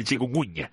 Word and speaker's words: chikungunya: Decía yo, el dengue, el Zika, la fiebre amarilla, o este virus que siempre me cuesta mucho pronunciarlo, que es el chikungunya. – El chikungunya.